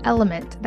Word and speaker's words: LMNT: This podcast is sponsored by Element. That's spelled L-M-N-T.